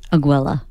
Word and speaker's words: Aguila: Aguila.